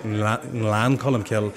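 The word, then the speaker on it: Glencolumbkille: And I remember the first week of getting the job, I was pronouncing Glen Colum Kill and I was going Glan Colum Kill, but to get the tongue up to the roof of the mouth and get Glan Colum Kill, where there's a wee N before the G, there's a lot of wee nuances there that I still work hard on.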